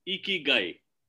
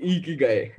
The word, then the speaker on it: Ikigai: So it's very simple. It's Ikigai. And right. The way you look at it, you know, there's a smile on the face. – Okay. – Do it. Which also with the cheeks up, you're always smiling. Ikigai.